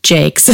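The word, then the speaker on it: Jacques: So I did learn this fun fact that the author, Brian Jakes, which I looked it up, it's pronounced Jakes.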